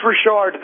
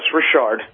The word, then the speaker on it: Rashart: It's Rashart. The T almost sounds like a D, but very close, Rashart.